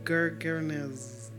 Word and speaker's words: Gergesenes: Is it Gergesenes or Genes? How do you spell it? How do you pronounce it? – Gergesenes. – Praise God. Gergesenes.